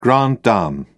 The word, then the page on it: grande dame: British English